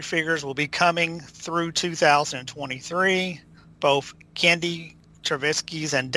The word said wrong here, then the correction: Tartakovsky: Dave Tartakovsky.